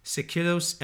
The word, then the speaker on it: Seikilos: But the oldest musical composition to have survived in its entirety is a 1st century AD Greek tune known as the Sikilos Epitaph, and that's spelled S-E-I-K-I-L-O-S, Epitaph.